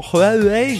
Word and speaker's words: Huawei: Autant j'ai intégré que Xiaomi, il fallait prononcer le X en ch, autant Huawei, j'ai du mal à prononcer le H comme le petit R qui va bien.